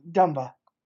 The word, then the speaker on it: Dumba: Dumba.